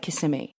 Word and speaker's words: Kissimmee: How do I say it? – We've always said Kissimmee. And that's what the agency say.